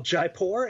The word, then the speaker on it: Jaipur: It's called Jaipur.